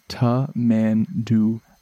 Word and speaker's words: tamandua: But it is pronounced ta-man-du-wa.